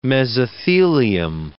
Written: mesothelium

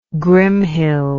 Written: Grimhild

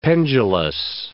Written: pendulous

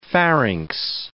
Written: pharynx

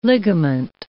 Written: ligament

